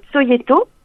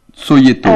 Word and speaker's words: Cojeto: Si vous avez accès à SoundCloud, par exemple, vous pouvez passer le titre Soyeto. – Soyeto. – Ça s'écrit, comme ça tout le monde le saura, oui. – C-O-J-E-T-O. – C-O-J-E-T-O.